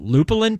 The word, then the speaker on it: lupulin: Got Place and Time with now I can't pronounce any of this because I'm an idiot, but lupulin powder? – Yeah, that is absolutely correct.